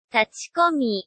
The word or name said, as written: tachikomi